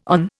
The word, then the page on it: on: Indian